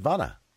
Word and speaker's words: Vonna: Is it Vonna? – Exactly like, like Donna with a V.